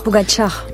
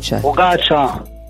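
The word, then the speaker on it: Pogačar: Pogacar. – Pogacar.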